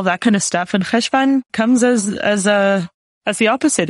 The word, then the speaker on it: Cheshvan: Welcome to the podcast for the month of Cheshvan. How did I pronounce that? – Perfectly.